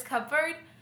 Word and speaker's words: cupboard: Aww, and someone, my friend, he was making fun of me, but he was like, it's pronounced cupboard, by the way. And I'm like, it's not spelled with a B. It's clearly a P.